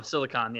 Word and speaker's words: Silicon: Oh, Silicon, yeah. Silicone is for breast implants.